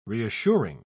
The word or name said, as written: reassuring